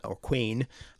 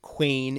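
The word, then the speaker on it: cwēn: So quain, I guess it's a long E, quain in Old English could mean queen in the sort of modern sense, but it could also just mean generally woman.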